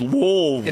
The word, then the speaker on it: Louisville: And when I said the word Louisville up here in Tennessee, you would have thought, like, it's Louisville. – It's one syllable, Louisville.